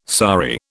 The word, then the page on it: sari: British English